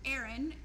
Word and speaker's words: Erin: And my name on Facebook is Erin, E-R-I-N, and my last name is really crazy. It's F-R-E-R-I-K-S.